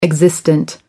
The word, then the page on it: existent: American English